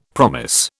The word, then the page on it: promise: British English